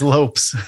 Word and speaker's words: Lopes: Lopes or Lopes? I'm so sorry, Jerrad. How do I say your last name? – Story of my life, man. It's Lopes.